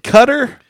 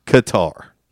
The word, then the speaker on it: Qatar: It's not Qatar. They call it Qatar. – You can pronounce it Qatar or Qatar. – Okay. – You can pronounce it either way.